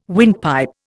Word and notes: windpipe: Indian

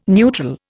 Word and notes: neutral: Indian

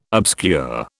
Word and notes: obscure: British English

